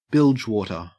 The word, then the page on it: bilgewater: Australian English